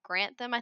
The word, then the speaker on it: Grantham: And then 2nd and 3rd of March are the second round of assessments, which are held at the PFI karting track near Grantham, I think that's how you say it, yep.